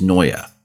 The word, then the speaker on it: Neue: But if you're reading it in German, it's Neue. – Okay. – And I mean, if you think about it, you can sort of imagine that second syllable in there. I'm not saying it is there, but you can imagine it being there.